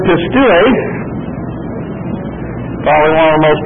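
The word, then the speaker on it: pistuo (πιστεύω): I hate to hear that they now pronounce this pistevo in Greece. I don't know if that has anything to do with our turncoat American Greek that's back there in charge of things, but nevertheless, they pronounce it pistevo. It's the old Greek pronunciation, of course, is pistuo.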